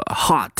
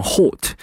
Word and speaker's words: hot: promise 这个道理和我们读 h o t 表示炎热的这个 hot 是一样的。那在英式英语中呢，我们读成 hot，但是在美式中呢，我们直接把它读成 hot 就可以了。